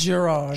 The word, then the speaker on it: Gerard: He's a soft G. Because he's French. – Yeah, of course. Yeah. Soft French. – It's Gerard.